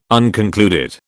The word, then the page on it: unconcluded: British English